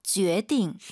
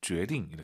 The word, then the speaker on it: 决定: 그리고 발음하실 때 决定, 많은 초급자분들이 선생님 이것도 决定, 决定 쌍디귿을 많이 하는데 사실 아하. 들어볼게요. 선생님 발음 决定. – 힘 빼고 발음하는 게 포인트예요. 决定. 이렇게 여유 있게 하시면 좋을 것 같습니다.